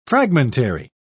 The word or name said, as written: fragmentary